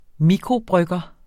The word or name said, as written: mikrobrygger